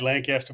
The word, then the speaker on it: Lancaster: It looks like Lancaster, but it's Lancaster.